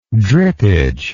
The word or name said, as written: drippage